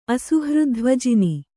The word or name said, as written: ಅಸುಹೃದ್ಧ್ವಜಿನಿ